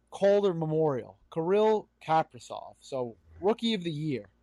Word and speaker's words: Kaprizov: I'm pretty sure it's Kaprizov, but either Kaprizov, way, it's fine. Yeah, Kaprizov.